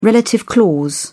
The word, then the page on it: relative clause: British English